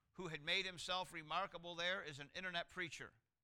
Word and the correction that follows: itinerant: It's not internet, it's itinerant.